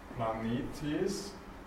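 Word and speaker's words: Planetes (πλανήτης): Planetes, Pi Lambda Alpha Nu. Eta, Tau, Eta, Sigma. Glaube ich nicht so schwer. Betonung auf dem ersten Eta.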